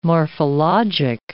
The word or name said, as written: morphologic